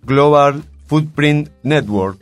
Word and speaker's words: Global Footprint Network: Global Footprint Network.